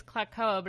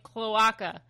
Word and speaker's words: cloaca: And I've been pronouncing it wrong for years, because I thought it was Klakoa, but it's like cloaca.